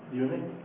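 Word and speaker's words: Jung: العبارة الأولى للعالم الفرنسي لعالم النفس عفوا الشهير بالعربي تكتب يونا. أنا أحتمل مثلا أنها يونج مثلا تنطق بالإنجليزية وكتب هو من أشهر تلاميذ فرويد يونج.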